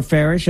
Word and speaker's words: Farash: Let me know on X at Sean underscore Farish, F-A-R-A-S-H. First name is Sean, S-H-A-W-N.